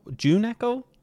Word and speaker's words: Juhn Echo: You're in a band, June Echo? Am I pronouncing it right? – That's very good. I say that's the first person to ever pronounce it the way we intended.